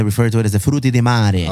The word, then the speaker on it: frutti di mare: But that's how you say it, bro. Frutti di mare.